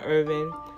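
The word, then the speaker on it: Ervin: And yes, I am Danielle Irvin, executive manager of Irvin Management Group.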